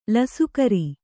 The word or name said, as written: ಲಸಕಿರಿ